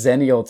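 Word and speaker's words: Xennial: But then I realized no, it's just the letter X, ennial, pronounced like yes, a Z.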